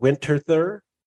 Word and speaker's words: Winterthur: You are originally from a city called Winterthur.